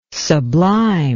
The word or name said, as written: sublime